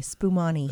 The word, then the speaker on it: Spumoni: Does that have a T in it? – No. S-P-U-M-O-N-I. Spumani.